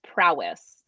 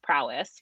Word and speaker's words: Prois: But when it came to upland hunting, there was pretty much, what is that, Prios? – I believe it's pronounced prowess. – Prowess. – You think so about that sounds it's spelled P-R-O-I-S, but correct. I think it's pronounced prowess, which makes sense when that does.